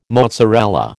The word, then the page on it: mozzarella: American English